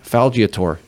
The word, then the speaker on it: Falgiatore: And we have Mr. Anthony Falgiator. Did I say it right? – You did.